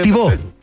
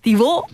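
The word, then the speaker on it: Thibaut: Tibat, Tibautu, Cortu, del Real Courtois. – gracias. El arquero. – Tibo Tibó. Tibó.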